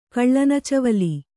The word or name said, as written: ಕಳ್ಳನಚವುಲಿ